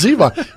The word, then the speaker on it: Zevon: You ever seen the interview Or is it Warren Zevon? Would you like to mispronounce his name too? – I know it's Zevon.